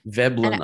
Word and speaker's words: Veblen: I'm not going to pronounce it probably correctly, but Veblen or Vablin goods? – Veblen, I think.